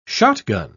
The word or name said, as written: shotgun